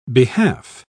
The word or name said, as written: behalf